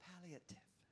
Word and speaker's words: palliative: There is a term, and, it's a nursing term, and it's called palliative. Did I say it right? Palliative. Thank you.